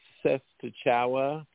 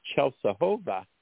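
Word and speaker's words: Częstochowa: And it looks like it would be pronounced Sestachowa, Poland. But I was corrected by a Polish person that it's pronounced Czesłowa.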